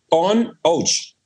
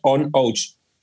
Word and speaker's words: Aonadh: Sí, eh, este Bunahaven es el, el eh, se escribe Aon ADH o Aon ADH, que se pronuncia On Ouch. Es la forma correcta de pronunciarlo. On Ouch. Que en gaélico significa para unión.